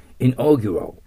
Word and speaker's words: inaugural: 次、 inaugural。 inaugural というのは、始める、 begin という意味なんです。 commence と似てますよね。はい。ただ、オーグというところに線引いてください。これが、発音、 OU にならないということは、オーガストとかと同じですよね。それ気をつけてくださいね。